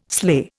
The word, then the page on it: sleigh: Indian